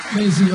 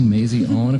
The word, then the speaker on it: Maisy: Maisie Owen, spelled M-A-I-S-Y, but it's pronounced Maisie. Maisie Owen.